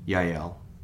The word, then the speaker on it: Yael: Little Miss Jail. – Yael. – No, it's a J. – Right. I looked it up because it's a biblical thing, and it's actually from the Bible, but it's pronounced Yael.